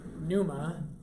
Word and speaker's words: pneuma: So when that word, so in Greek the word is pneuma, with a P, silent P.